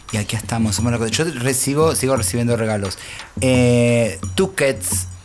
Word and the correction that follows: Tusquets: Tusquets, siempre lo digo mal,